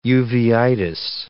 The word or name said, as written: uveitis